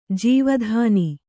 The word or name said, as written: ಜೀವಧನ